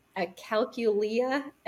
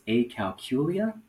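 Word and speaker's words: acalculia: This is one of those words that it can be pronounced different ways. So similar to like tinnitus or tinnitus, can either say it's a calculia or a calculia. And either one is totally fine.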